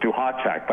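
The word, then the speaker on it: Duhatschek: T and the S are actually silent, so it's Duhashek.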